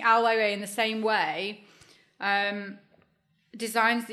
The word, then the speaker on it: Ai Weiwei: Do you the question there is, do you think that, for example, Ai Weiwei, if I've said that right. – You said it right. You said it beautifully. – Ai Weiwei.